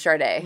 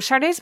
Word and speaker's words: Sade: So, in retrospect, you know, Sade's been around for a long time, and the pronunciation of her name to those of us in North America has always been like an invisible R in the middle of her name, Sade.